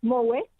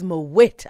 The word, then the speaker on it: Moët: I had to learn to say it properly as well, but it's Moët and Chandon. – So it is Moët after all.